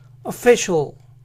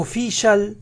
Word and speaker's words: official: Entonces la palabra completa se pronuncia official, official, official. ¿Notan cómo las vocales tienen sonidos diferentes a las que usamos? No es oficial u official, sino official, official.